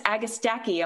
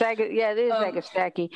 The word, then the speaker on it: Agastache: The folks at American Meadows, I stopped by their booth once and I was like, is it Agastache or I had another pronunciation of it, and they said, no, it's Agastache. I'm like, are you kidding me? So it's Agastache.